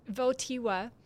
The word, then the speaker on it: Wojtyła: It's a series of reflections or catechesis, a series of teachings that then Archbishop Karol Wojtyła of Krakow. Did I say that right? – I think you got it, yeah. – Yes, Wojtyła.